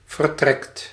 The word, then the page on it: vertrekt: /vər.ˈtrɛkt/